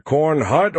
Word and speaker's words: Cornhut: Baker asked me on the tactical channel, "Did he just say his name is Lester Cornhut or Cornhole?" – "Cornhut," I replied, with the translator function paused. "There's a T sound on the end of it."